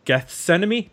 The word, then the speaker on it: Gethsemane: We're going to be talking about the album from 1993, Gethsemane.